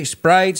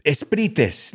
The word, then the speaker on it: Sprite: Dos sílabas comparado con una. Eso dices, ok, Sprite, Sprite, hombre, al final cuando vas a pedir algo, ¿sabes? Give me eh, esprites.